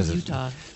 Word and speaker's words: Eutaw: Now, it is spelled, by the way, E-U-T-A-W. – Oh, it doesn't matter. – But it's still Utah. – So it's a hillbilly-ish phonetic rendering of Utah right.